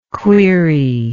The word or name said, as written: cuirie